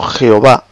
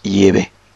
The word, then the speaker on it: Yahvé: Efectivamente, la pronunciación Jehová es absolutamente desconocida en los manuscritos, textos bíblicos, por los propios judíos. Es un término totalmente desconocido. Carece de sentido, efectivamente, dice Anita. Es un nombre realmente falso, es un nombre híbrido. Ese no es el nombre de Dios. El nombre de Dios, la pronunciación más correcta en hebreo arcaico, es Yahvé. En hebreo antiguo, arcaico, que es que escribió el Antiguo Testamento, pues parece ser que es Yahvé.